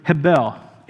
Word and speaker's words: hebel: That word is the word in the Hebrew, hebel. It's spelled H-E-B-E-L. Put the kind of the phonetic way you would say it, hebel there.